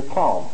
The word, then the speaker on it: calm: You know, it seemed to me that was like calm. You know, when you're not excited, you're calm. It is, because that's pronounced calm. None of them do you hear the L. That may be the way you pronounce it, but they're not there. They're all silent L's.